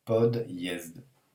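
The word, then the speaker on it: pod yezd (подъезд): Ce mot-là, P-O-D, signe mou, I-E-Z-D. Et ça se prononce pod, et la fin ça se prononce yezd, pod yezd, pas d'yezd.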